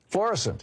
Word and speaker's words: Florissant: Picking up your calls here, Florissant, Missouri. Am I saying it right? – Florissant.